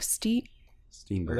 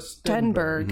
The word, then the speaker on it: Stenberg: I'm going to butcher her name. Amandla Stenberg? – Stenberg. – That was it. Amandla Stenberg.